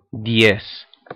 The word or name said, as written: diez